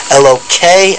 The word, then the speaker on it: Elokei: So the way you pronounce it, if you read it, is Elokei Avraham ve'lohei Nachor.